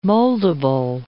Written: moldable

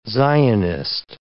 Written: Zionist

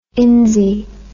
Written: insy

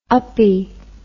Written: uppy

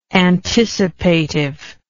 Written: anticipative

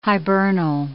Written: hibernal

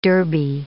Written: derby

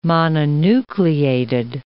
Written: mononucleated

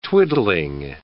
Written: twiddling

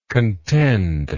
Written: contendingly

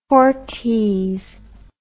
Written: fortes

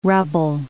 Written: rubble

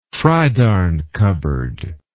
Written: tridarn cupboard

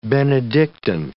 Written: Benedictine

